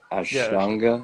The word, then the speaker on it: Ashtanga: But yeah, you're talking about the Ashtanga yoga. Am I saying it right? Ashtanga? – Yes, I think so.